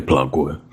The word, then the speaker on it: Plague: Plague. Eh, pronunzialo bene, Co- come dite voi, è Plague.